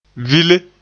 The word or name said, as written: vile